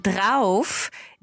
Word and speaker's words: drauf: Ja, G-U-T, yeah. Und dann drauf, D-R-A-U-F.